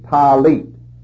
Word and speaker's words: talit: Now that prayer shawl is called a talit. You spell that T-A-L-I-T, talit. And the fringes on the border of that garment are called tzitzit, T-Z-I-T-Z-I-T, tzitzit.